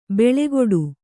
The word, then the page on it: ಬೆಳೆಗೊಡು: beḷegoḍu